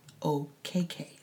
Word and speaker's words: Okeke: OKK.